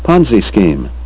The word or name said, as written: ponzi scheme